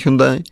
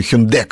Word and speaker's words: Hyundai: Солярис номер один на сегодняшний день Hyundai – или Hyundai, как они Mm-hmm. – вот сегодня просят произносить.